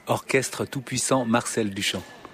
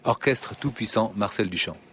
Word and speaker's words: Orchestre Tout-Puissant Marcel Duchamp: Orchestre Tout-Puissant Marcel Duchamp. – One more time, please. – Orchestre Tout-Puissant Marcel Duchamp.